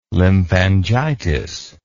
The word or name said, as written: lymphangitis